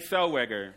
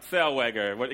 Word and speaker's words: Zellweger: Renee Zellweger. Of Zellweger, of hoe is ook hoe naam uitgesproken wordt. Zellweger. Ik word gecorrigeerd door de zaal.